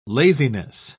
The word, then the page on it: laziness: British English